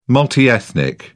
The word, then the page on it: multi-ethnic: British English